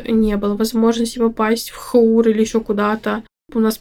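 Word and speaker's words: jour: А, поэтому можно, а, как я уже сказала, там вечер, выходной день, когда поликлиника не работает, а, есть дежурная поликлиника, она называется ХУР, пишется как ЖУР, многие называют ЖУР, как в слове «хуналист», «журналист» по-шведски «хур» — дежурство.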